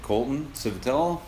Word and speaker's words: Colton Civitello: We'll keep talking just like we are, but I should say, welcome to BMX in our blood, Colton Civitello.